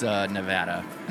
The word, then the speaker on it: Nevada: If I recall, like in our first couple episodes, Hunter reached out to us like, hey, FYI, it's Nevada.